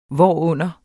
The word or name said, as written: hvorunder